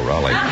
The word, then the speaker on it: Roly: No, Raleigh. – You realize, of course, that we can be sued by Abbott and Costello. How do you spell it? – R-O-L-Y. – Oh, Roly. Can you pronounce it Roly? – Yes, sir.